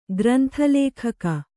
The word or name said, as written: ಗ್ರಂಥಲೇಖಕ